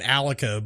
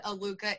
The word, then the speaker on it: Aluka: RJ from the band Alaka, but it looks like it's spelled Aluka. I'll never forget that one. Yeah, that's... I have always said Aluka in my head, and okay, well, now I know that's wrong.